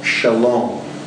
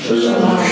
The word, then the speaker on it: Shalom: And it's in the Old Testament, and it's called Shalom. Shalom. It's a Hebrew word. Go ahead and say it with me. – Shalom. – Shalom. Shalom. That word, the accent of the second syllable, Shalom.